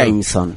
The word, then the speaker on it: Jameson: Jameson. – Jameson. Jameson. Jameson. Sí, la E no la, no, no, pronuncias la E, digamos, se raya en la M.